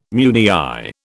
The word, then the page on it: meunière: British English